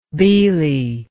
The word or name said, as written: Bhili